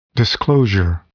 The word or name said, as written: disclosure